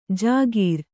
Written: ಜಾಗೀರ್

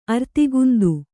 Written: ಅರ್ತಿಗುಂದು

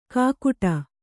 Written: ಕಾಕುಟ

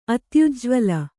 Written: ಅತ್ಯುಜ್ವಲ

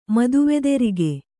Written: ಮದುವೆದೆರಿಗೆ

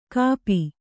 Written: ಕಪಿ

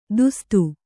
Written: ತುಸ್ತ

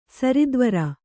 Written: ಸರಿದ್ವರ

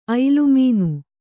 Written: ಐಲುಮೀನು

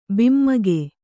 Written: ಭೈಮೂಗು

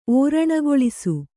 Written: ಓರಣಗೊಳಿಸು

